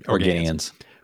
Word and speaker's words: Organians: Organians? – Yep. – Can't remember how they pronounce it. – Organians.